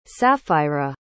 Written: sapphira

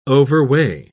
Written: overweigh

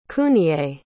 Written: Kuniye

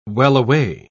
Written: wellaway

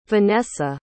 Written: venessa

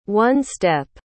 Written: one-step

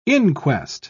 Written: inquest

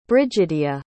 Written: Brigidia

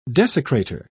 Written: desecrater

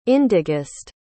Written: indigest

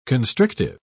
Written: constrictive

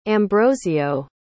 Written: ambrosio

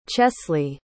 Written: chesley

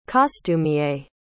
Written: costumier

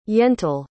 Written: yentl

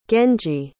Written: genji

